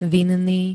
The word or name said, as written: vinný